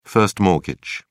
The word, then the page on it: first mortgage: British English